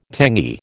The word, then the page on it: tangy: American English